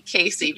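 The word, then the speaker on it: Kaycee: My mom named me Casey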